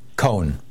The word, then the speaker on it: Cohn: Then finally McCarthy and Ray, you said Ray Kahn or Ray Cohen? – Cohen. One syllable. – Cohen, okay, C-O-H-N.